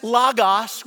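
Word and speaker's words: logos (λόγος): But I was taught it as logos with the omicron, short O, logos.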